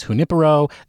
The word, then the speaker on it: Junipero: And since they said it's Spanish, I'm going to say that the pronunciation of the gin is junipero.